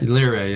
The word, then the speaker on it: Liray: So, like, we met Liray. Am I pronouncing that right? – Liray, yeah.